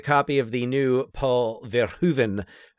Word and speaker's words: Verhoeven: And I did get from, I got the official pronunciation to make sure that we were accurate. Verhoeven.